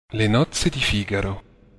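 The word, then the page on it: Le nozze di Figaro: Italian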